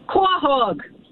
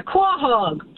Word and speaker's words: quahog: Quahog. – All right. – It's a quahog. Q-U-A-H-O-G. Quahog. – Quahog. That's right. Thank you. – That's right. That's the only one way to say it. – Q-U-A.